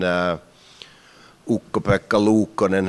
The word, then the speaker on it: Ukko-Pekka Luukkonen: I think the best season Ukko-Pekka Luukkonen has ever had.